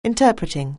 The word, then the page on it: interpreting: anglais